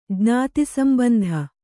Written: ಜ್ಞಾತಿಸಂಬಂಧ

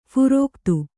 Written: ಫಾರೀಕತ್ತು

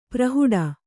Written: ಪ್ರಹುಡ

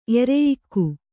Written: ಎರೆಯಿಕ್ಕು

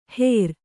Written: ಹೆರ್

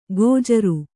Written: ಗೋಜರು

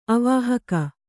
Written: ಅವಾಹಕ

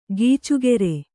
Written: ಗೀಚುಗೆರೆ

